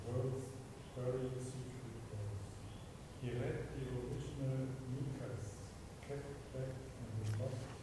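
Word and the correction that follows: ukase: What's the correct pronunciation? – Ukas. – Ukas. Thank you. It's a Russian word.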